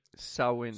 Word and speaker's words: Samhain: Well, apparently the origin of Halloween can be traced back to Samoan, which is pronounced Sao, in which rhymes with cow. So it's actually Samhain.